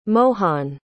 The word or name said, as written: mohan